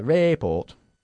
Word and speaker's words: report: Okay, according to the report, not the report. Okay? According to the report. – According to the report. – No, estás en el report. – Report. – Report. Enfatizando la segunda sílaba ahí, ¿vale? – According to the report...